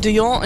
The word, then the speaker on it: Duillond: And Duyon is, I always am tempted to because of the spelling, to pronounce it a little bit French. But it's actually Sindarin.